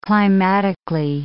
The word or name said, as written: climatically